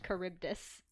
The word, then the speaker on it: Charybdis: Know of it as being pronounced Charybdis.